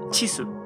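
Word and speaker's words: chisu (チーズ): Pero ellos solamente pronuncian chisu, es decir, solo pronuncian la parte del queso.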